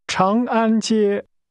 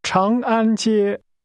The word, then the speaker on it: 长安街: 长安街长安安 Chang Chang An Chang An Jie. – That's pronounced with first a rising tone, then two high level tones. Say again, Long Peace Street. – Chang An Jie.